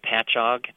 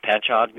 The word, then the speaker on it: Patchogue: Now, you own Spectrum Physical Therapy in New York, and I'm going to try and say it right, Patchogue. Did I get that right? – Yeah, that's correct. – Patchogue, New York.